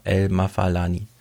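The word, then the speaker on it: El-Mafaalani: Aber was mir bei solchen Berichten immer wieder auffällt, und ich will nochmal daran erinnern, äh, das Integrationsparadox von Aladdin El Mafalani, habe ich es richtig ausgesprochen? Ja, El Mafalani.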